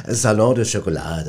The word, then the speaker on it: Salon du Chocolat: Ja, das Salon du Chocolat, das hast du schon ganz schön ausgesprochen, gut. – Schon ganz richtig.